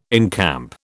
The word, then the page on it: encamp: British English